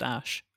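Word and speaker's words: Æ: I mean, AE apparently is pronounced Ash.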